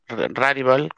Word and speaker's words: Rarible: Empecé con Rarible, Rarival, se dice así en inglés, y por mercado prácticamente porque se fue todo a OpenSea, me moví a OpenSea.